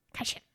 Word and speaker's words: Kashyyyk: And Kashyyyk. – Kashyyyk.